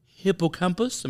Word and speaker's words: hippocampus: As it compromises the neurology in the hippocampus. Am I saying that right? – Yes, you are.